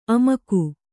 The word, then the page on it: ಆಮೋಘ: Kannada